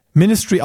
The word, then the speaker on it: MNSTRY: Ministry ausgesprochen, geschrieben M-N-S-T-R-Y.